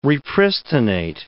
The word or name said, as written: repristinate